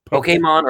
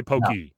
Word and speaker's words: Pokemon: Pokemon or Pokemon. Those are the acceptable ones. – Not a long E. Don't say Pokey. – No, don't make it E.